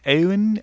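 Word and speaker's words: Eoin: It's E-O-I-N. I think it's like Owen Colfer, who wrote And Another Thing, which I'm partway through, and I have to say I'm kind of impressed.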